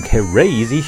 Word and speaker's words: K-Razy: Crazy Shootout. I was trying to figure out how to pronounce it. K-Race. Some people said crazy. I like that.